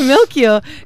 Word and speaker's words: Melchior: And Ryan, so you play the male lead, Melchior. Did I pronounce that right? – Yeah. – Amazing. So Melchior, who's really smart and calls out the corruption in society.